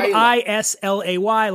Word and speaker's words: Islay: So it's Islay. It's spelled I S L A Y. A lot of people mispronounce it Islay, but it's Islay.